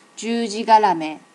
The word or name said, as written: jujigarame